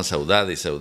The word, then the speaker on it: Saudade: Este señor, la otra vez habíamos escuchado a Maro, una cantante portuguesa con una canción llamada Saudade, muy bonita, y pronuncio Saudade, no Saudade. – Hace muy poquito, dos programas. – Exacto, porque era portuguesa, no brasilera, donde se pronuncia Saudade.